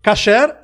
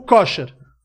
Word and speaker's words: kosher: kasher ou kosher. – Depende é, da pronúncia. – Eu via kosher. – É. é. Depende se a pronúncia é ashkenazi ou faradi.